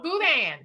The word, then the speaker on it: boudin: No, it's called boudin. Boudin. B-O-U-D-I-N. Some people spell it D-A-I-N.